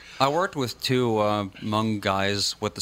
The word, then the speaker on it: Hmoob: I still want to know how H-M-O-O-B is pronounced Hmong.